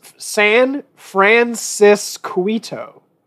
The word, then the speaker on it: San Francisquito: San Francisquito? – It's San Francisquito. – That's too many syllables.